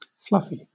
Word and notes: fluffy: British English

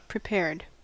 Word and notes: prepared: American English

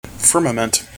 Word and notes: firmament: American English

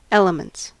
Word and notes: elements: American English

